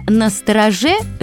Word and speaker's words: настороже: А вот наречие «настороже» живет. И живет именно с таким ударением. Так что будьте настороже. Произносите это наречие правильно.